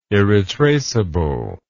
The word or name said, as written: irretraceable